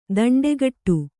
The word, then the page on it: ದಿಂಡುಗೆಡೆ: diṇḍugeḍe